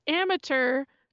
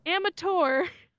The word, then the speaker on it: amateur: He did not pronounce amateur to rhyme with blur. He said amateur or amateur, which is a totally valid way to say amateur. Amateur, amateur. But you're rhyming with blur. Say it to rhyme with blur, please.